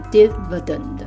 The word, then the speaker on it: dividend: 那这个字转成名词，变成 dividend，dividend，嗯，发音要稍微转一下哦，从 divide 变成 dividend，那变成分割开来切给大家的钱费，所以分。